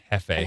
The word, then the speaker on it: Jefe: Am I pronouncing that right? El Jefe? – Jefe. – Jefe. El Jeef.